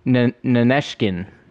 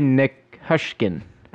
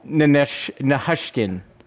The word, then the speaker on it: Nichushkin: Nineshkin. – So let me he said Nineshkin. His last name is spelled Nichushkin. Nineshkin. – Valerie Nichushkin.